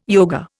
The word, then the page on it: yoga: Indian